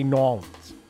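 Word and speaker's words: New Orleans: 1100 Constance Street in Nolans, if you want to pronounce it like that. I always found that to be pretentious. If you're not from there, if you're from there, go ahead, say Nolans.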